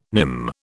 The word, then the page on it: nim: British English